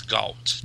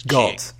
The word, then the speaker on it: Galt: Well, I'll get into this later, but it's Galt. – Galt. Okay. Yeah. As if it had like a U in it. It's G-A-L-T, but it's right. Kind of like, okay, gotcha. Galt House.